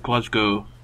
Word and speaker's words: Glasgow: How is it? – Glasgow. – Glasgow gotcha. Instead of Glasgow? – Yeah. It's silent W.